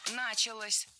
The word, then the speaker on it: началась: Началась. Похожая ошибка, примерно такая же, что и в предыдущем примере, но у нас здесь женский род. Опять же, возвратная часть «с» притягивает ударение к себе. Началась. Ударяем последний слог.